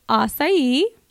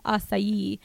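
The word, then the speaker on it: açaí: And it almost sounds a little Asian, like it sounds acai, you know? And a lot of our girls say it like that because it just makes it fun. But it's acai. So it's acai, not acai.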